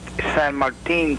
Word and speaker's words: San Martin: Number one, to John Bowker, I'm not terribly sure that the 1120 signal in San Martin, or as it's pronounced, San Martin, California, is actually on the air.